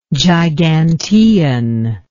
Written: gigantean

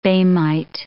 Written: boehmite

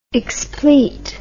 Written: explete

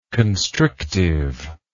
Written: constrictiveness

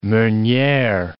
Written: meuniere

